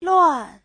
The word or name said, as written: luan4